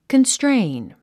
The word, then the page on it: constrain: kənstréin; カンストレイン